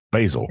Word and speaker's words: Basil Hayden: How do you say that? Basil or Basil? – Basil. Basil Hayden.